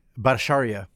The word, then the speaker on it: Bhattacharya: Bhattacharya.